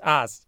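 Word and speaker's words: AS: There's one S. Come on. – Yeah, it's just like A-S, ass. – Bro, you need to talk to Sid Lowe. It's ass. It's totally ass. I'm talking ass here.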